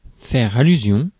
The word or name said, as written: faire allusion à